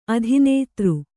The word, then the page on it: ಅತಿನಿದ್ರೆ: ati nidre